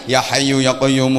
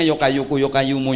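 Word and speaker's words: Ya Hayyu ya Qayyum: Harusnya kan ya hayu ya qayyum, ya hayu ya qayyum, ya hayu ya qayyum, ya hayu ya qayyum. Tapi bacanya yo kayuku yo kayumu yo kayuku yo kayumu yo kayuku yo kayumu.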